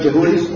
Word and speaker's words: Jehudi: And it came to pass that when Jehudi, am I pronouncing it right? Correct, Jehudi? – That's right.